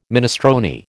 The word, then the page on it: minestrone: American English